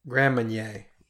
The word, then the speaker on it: Grand Marnier: You know, another thing that I noticed, I was just reading about Disney treats, the Grand Marnier, that's how you say it, right? Marnier? – Grand Marnier.